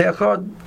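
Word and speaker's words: echad (אחד): So therefore, it's suggested for many people who do not find the ability to extend the dalet, so they should just say echad. And when they say echad, so they pronounce the dalet with a shwa noch. It's not a shwa noh, which the word noh means to go or to travel. It's not going anywhere, but rather it's noch, it's resting, it's staying put.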